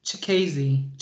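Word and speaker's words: Chikadze: He kept getting uh how do you say his last name? Uh Chikazi? Uh oops. – Chikazi. Chik yes, like Chikazi.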